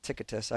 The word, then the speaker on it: Tychicus: When I send Artemis or Tychicus to you, I had to write this out because I couldn't pronounce Tychicus. I kept adding letters to it. My wife's like, there's no extra T in there.